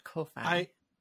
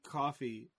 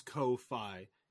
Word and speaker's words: Ko-fi: Yeah, Ko-fi. – I hear people say coffee, but I am. It is Ko-fi.